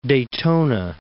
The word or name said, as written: Daytona Beach